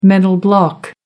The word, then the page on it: mental block: American English